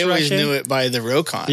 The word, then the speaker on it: Rokon: And then when people saw the name written down, they pronounced it Rokon. And that's how Rokon got its name. It should probably actually be pronounced Rock On because he named it after On the Rocks. But when people see R-O-K-O-N, it looks Russian.